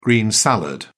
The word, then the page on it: green salad: British English